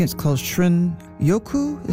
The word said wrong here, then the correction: shinrin-yoku: Is that how you pronounce it? The Japanese term for wood air bathing. – Shinrin-yoku. – Shinrin-yoku.